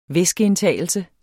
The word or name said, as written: væskeindtagelse